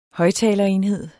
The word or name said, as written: højttalerenhed